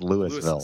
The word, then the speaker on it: Louisville: I think it's pronounced Louisville.